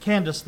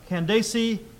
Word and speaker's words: Candace: I pronounce it Candace, the queen. I've also heard Candace.